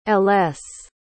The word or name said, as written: ellesse